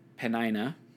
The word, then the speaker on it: Peninnah: And it was a sense of animosity between her and of the other wife, Peninnah. That's how we're going to go ahead and say that's pronounced.